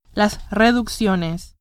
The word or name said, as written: reducciones